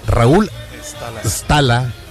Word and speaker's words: Stala: Así se pronuncia, Raúl Stala, con S.